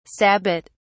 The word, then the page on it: sabbat: British English